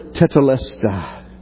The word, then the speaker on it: tetelestai: tetelestai. And see how it's built. See the root of it. And stand in staggered amazement when you realize this one little word with just a few letters, four syllables, says it has been accomplished and it stands accomplished, never to be undone.